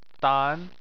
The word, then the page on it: dan: 2